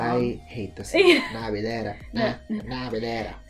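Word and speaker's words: Nabulera (나빌레라): okay, so it's either, I see both, it's Naviolera or Nabulera. I'm pretty sure... – yeah, I think it's Naviolera, but the pronunciation is Nabulera. – Yeah, Nabulera. Yeah.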